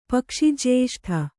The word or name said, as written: ಪಕ್ಷಿಜ್ಯೇಷ್ಠ